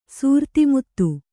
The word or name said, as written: ಸೂರ್ತಿಮುತ್ತು